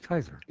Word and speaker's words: Caesar: And in fact, probably the proper pronunciation of the family name in Rome, as Romans spoke it, was not Caesar, but Kaiser.